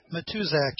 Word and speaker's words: Matuszak: Matuzak, M-A-T-U-S-Z-A-K, something like that. – Oh, okay. I thought you said Tushak. – Remember, I pronounce it like the Poles do.